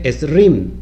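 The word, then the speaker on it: Esrim (עשרים): Fíjate, 20, que es lo que vale el valor numérico o gemátrico de la letra Kaf, 20 eh, en hebreo se escribe Esrim, 20 en hebreo se escribe Esrim, así como lo estás viendo, Aleph, Shin, Reish, Yud y, y Mem, Mensofit.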